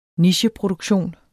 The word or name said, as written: nicheproduktion